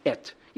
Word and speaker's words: et: It's pronounced et. You know, like E.T.? No, not extraterrestrial. But it's pronounced like that. E.T., no, et.